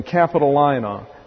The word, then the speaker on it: Capitolina: It's A-E-L-I-A, pronounced Aelia, and then Capitolina, C-A-P, I think it's O rather than A, C-A-P-I-T-O-L-I-N-A, Capitolina.